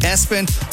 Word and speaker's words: ESPN: Also, Lester on that list, the name ESPN, pronounced Espen.